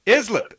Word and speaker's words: Islip: Islip. – East Islip, New York. I figured since it was an IS, it would maybe sound like island, but I do stand corrected on that. East Islip, New York.